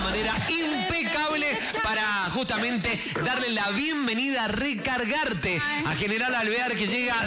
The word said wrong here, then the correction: Recargate: Creo que lo había dicho mal. Recargarte. O capaz que lo pronuncié mal. – No, él lo está diciendo mal. Es recargate.